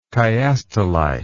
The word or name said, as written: chiastolite